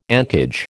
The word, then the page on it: encage: American English